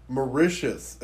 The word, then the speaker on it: Mauritius: Mauritius. – Mauritius is how it's pronounced.